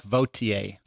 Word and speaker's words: Vautier: I'll say my last name. It's Clark Vautier. – Vautier. – Yes, it's French.